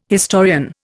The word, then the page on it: historian: Indian